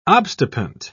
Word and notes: obstipant: British English

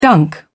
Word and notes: dunk: British English

